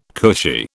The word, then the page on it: cushy: British English